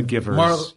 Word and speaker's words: McGivers: Yeah, Marla McGivers. – Well. It's actually MacGyver's. He always pronounced her name wrong, MacGyver's, and she had to correct him.